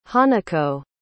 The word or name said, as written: hanako